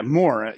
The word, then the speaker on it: Moore: Yeah, Moore.